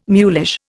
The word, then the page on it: mulish: Indian